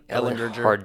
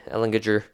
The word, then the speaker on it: Ellinger: So, or Ellinger. – I mean, Ellinger. Hard G. Ellinger.